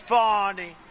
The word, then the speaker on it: Fani: F-A-N-I, then we'll get to the phones. – That's pronounced Fanny, but you're not allowed to say it. It's Fawny.